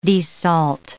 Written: desalt

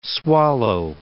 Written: swallow

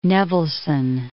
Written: Nevelson

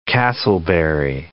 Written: Casselberry

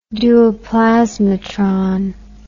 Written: duoplasmatron

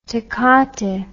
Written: tecate

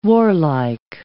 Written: warlike

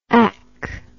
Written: ack

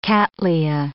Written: cattleya

